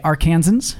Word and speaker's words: Arkansans: I was in Arkansas this week, and by the way, Arkansans, which is how you say it, very friendly.